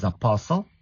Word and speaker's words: apostle: It's apostle, apostle, listen, listen. Very good. The T is silent.